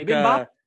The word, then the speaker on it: bibimbap: Is that how you say it? – Yeah, it's like bibimbap.